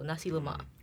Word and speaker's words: nasi lemak: So the first word is nasi, rice. It's spelled N-A-S-I. – Mm-hmm. Mm-hmm. – And the second word, lemak, L-E-M-A-K. So nasi lemak.